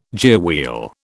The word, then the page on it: gearwheel: British English